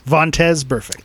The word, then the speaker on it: Vontaze Burfict: Vontaze Burfict.